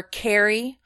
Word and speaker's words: Kari: So NFC underscore Carrie. And if you don't know how to spell my name, the proper way to spell it is K-A-R-I.